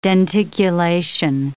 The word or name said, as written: denticulation